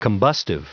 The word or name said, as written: combustive